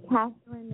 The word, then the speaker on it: Catherine: My Facebook is Catherine Nicholson, which is spelled C-A-T-H-E-R-I-N, Faith Nicholson, N-I-C-H-O-L-S-O-N.